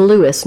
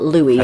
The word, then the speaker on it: Louis: It is important, I'm glad that you said this, it is pronounced Lewis, not Louis.